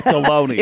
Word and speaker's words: Delaney: You're not close. Delaney. – Yeah, Delaney. – It rhymes with baloney.